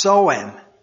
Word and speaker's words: Samhain: But Halloween is a name for the eve of, and I'll spell this for you, S-A-M-H-A-I-N, and it's pronounced Sowin. I didn't get that pronunciation out of that, but that's what it says. That's how it's pronounced.